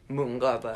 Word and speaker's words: Mönchengladbach: Mönchengladbach.